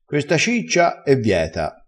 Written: vieto

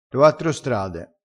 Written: uattro

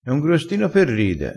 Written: grostino

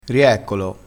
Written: rieccolo

